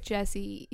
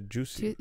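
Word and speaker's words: Jussie: I would have said, like, Jesse Smollett. – Yeah, but that's not... Yeah, there's a U. It's a Juicy.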